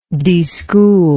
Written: deschooler

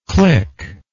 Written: clickless

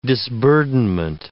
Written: disburdenment

